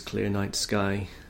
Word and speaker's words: ClearNiteSky: I may also be known as Clear Night Sky, which uh, is spelled C L E A R N I T E S K Y.